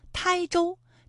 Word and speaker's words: 台州: 它还有一个读音啊，是一声胎，台州，这是我们国家的一个地名哈，还有天台是一个山名，它都在浙江省。